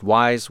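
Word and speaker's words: Weyes: It's W-E-Y-E-S, like Waze, but pronounced Wise.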